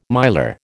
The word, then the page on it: miler: American English